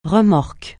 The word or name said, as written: remorque